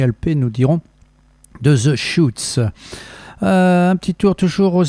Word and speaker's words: Chutes: Après This Is Lorelai, vous avez pu écouter de Schutz, un groupe de San Francisco. Alors Schutz, ça s'écrit comme chute en français, C-H-U-T-E-S.